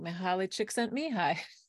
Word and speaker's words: Mihaly Csikszentmihalyi: So that's how I just let it roll off my tongue, Mihaly Csikszentmihalyi.